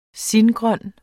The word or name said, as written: singrøn